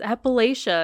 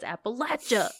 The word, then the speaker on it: Appalachia: In Appalachia, pronounced correctly, thank you to my husband who said I said it wrong. – And she didn't. – He said it's Appalachia, and I said it's Appalachia. Sorry.